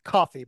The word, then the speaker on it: Ko-fi: Is it Ko-fi or is it coffee? – I think it's pronounced coffee, but it's spelled Ko-fi donation.